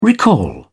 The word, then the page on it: recall: British English